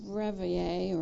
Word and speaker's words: reveille: So when we were doing the taps and reveille, or reveille, however it's pronounced, Jean, how is it pronounced? Reveille.